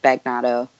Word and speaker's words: Bagnato: You know, I grew up and my dad pronounced it Bagnato. And I just recently I thought, well, why are we doing this, man? This isn't how you say it.